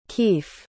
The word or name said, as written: Keef